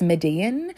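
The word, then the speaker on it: Medellín: Ramo travelled to the city of Medellin. I for some reason I add an extra syllable to that. It's Median, um, how you say it,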